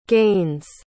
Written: Gaines

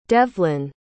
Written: devlyn

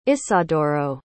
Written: isadoro

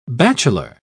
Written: bachelor

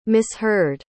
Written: misheard

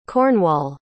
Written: Cornwall

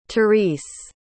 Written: therese

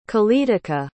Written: qaletaqa

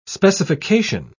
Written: specification

